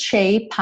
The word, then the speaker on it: shape: In this shape I'm in. Shape I'm in. Ok? Shape I'm. Porque o shape termina com E. O E não é pronunciado. Logo, P é o último som dessa palavra.